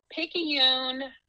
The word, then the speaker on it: Picayune: How do you pronounce it? – Oh, I'm used to it. It is Picayune, Mississippi. – Wow. Okay. So Picayune, Mississippi is where our sister's from.